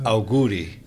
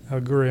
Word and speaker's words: auguri: Auguri. – Auguri.